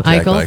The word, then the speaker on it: Eichel: This was a series of short audio stories created by romance authors featuring stars like Connor McDavid, Jack, I don't know how to say his Eichel. Last name. Eichel, thank you.